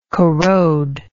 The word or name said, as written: corrodability